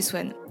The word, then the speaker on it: Swan: Et le toi et bienvenue dans mon podcast du côté de chez Swan. Et oui, c'est comme ça que ça se prononce.